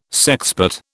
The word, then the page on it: sexpot: British English